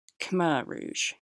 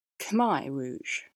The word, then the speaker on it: Khmer Rouge: Last episode, I used the pronunciation Khmer Rouge, but apparently that's not the Cambodian pronunciation. It's Khmer Rouge.